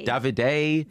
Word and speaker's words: Davide: How are we pronouncing his name? Is it Davide? – Davide. – Yeah. Davide. Gemma and Davide.